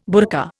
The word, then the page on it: burka: Indian